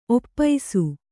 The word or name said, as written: ಒಪ್ಪೈಸು